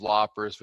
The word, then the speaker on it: loppers: I love that you guys that you smash him so hard for the loafers, because it's just a mispronunciation of loppers, which I just think is great.